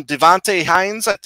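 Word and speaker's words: Devonté Hynes: But other than that, you get a little added vocals from Devante Haynes, that's how you pronounce his name.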